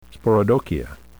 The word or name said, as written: sporodochia